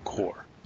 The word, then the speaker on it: Corps: Or Corps, which would be the proper way to pronounce it. Corpse would be a dead body.